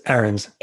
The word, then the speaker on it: Ahrens: Close. It's Ahrens.